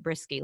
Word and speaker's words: Brueske: Looks like brewski, but we pronounce it brisky, like a brisk day.